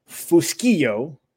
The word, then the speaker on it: Fuschillo: I'm your host, Jeremy Wolf, and today I am joined by Nunzio Fuschillo with Effe Cafe right here in Cooper City.